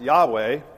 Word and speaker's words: Yahweh: It's just a series of Hebrew consonants, which we pronounce Yahweh.